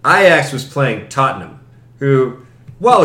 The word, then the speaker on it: Ajax: Ajax.